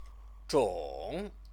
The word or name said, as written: zhong